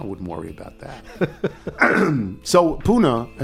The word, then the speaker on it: Pune: The only Indian city that I think I might be close to Pune is Pune, which is pronounced Pune.